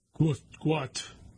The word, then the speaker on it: Gout: In 2005, Bona, B-O-N-A, and Monica Gout, pronounced Gout. Gout, spelled G-O-U-T, is pronounced G-W-O-T, Gout, sought to leave war-ravaged South Sudan.